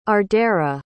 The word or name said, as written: ardara